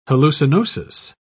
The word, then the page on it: hallucinosis: British English